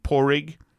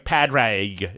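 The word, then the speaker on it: Pádraig: S-I-O-B-H-A-N. And have you ever tried to spell the name Porig? – No. – It's like Padraig.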